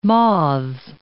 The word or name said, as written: moths